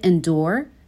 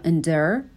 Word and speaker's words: endure: endure یا endure دو تا تلفظ داره بیشتر توی تلفظ آمریکایی صدای او داره